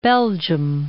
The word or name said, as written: Belgium